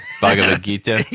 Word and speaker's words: Bhagavad Gita: I can't pronounce it. – Bhagavad Gita. – You're right.